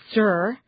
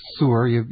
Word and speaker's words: tsur: And that word for strength is tsur, and it means rock. – Yeah, it's actually tsur. You got to pronounce that T first. Tsur.